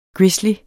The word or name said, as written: grizzly